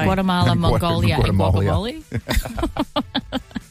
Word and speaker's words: Guatemala: Is it Guatemala? Is it, how do you say it? – Guatemala.